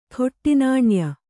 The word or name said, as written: ಖೋಟಾನಾಣ್ಯ